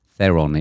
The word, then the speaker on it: Theron: I didn't realise I've been saying it wrong all the time. Watch the programme. Theron it is.